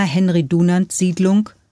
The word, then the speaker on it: Henry-Dunant-Siedlung: In der Sossenheimer Henry-Dunant-Siedlung, dessen Name übrigens im Volksmund nicht auf Französisch, sondern einfach Deutsch, wie man es schreibt, ausgesprochen wird, ist Serke aufgewachsen.